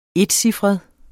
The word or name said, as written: encifret